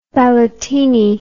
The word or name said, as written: ballotini